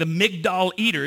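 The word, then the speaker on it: Migdal Eater (מגדל עדר): The Migdal Eater is how you say it in Hebrew.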